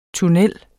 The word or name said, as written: TUNNELLER